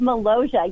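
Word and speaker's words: Molossia: Tell us about the Republic of Malaysia. Am I pronouncing it right? – You are correct. The Republic of Malaysia.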